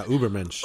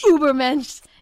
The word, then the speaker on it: Übermensch: And then it gradually like transformed into his updated like portrait right now for his latest album, Übermensch. Is that how you pronounce it? – Yeah, Übermensch. – Übermensch.